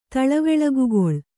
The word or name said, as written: ತಳವೆಳಗುಗೊಳ್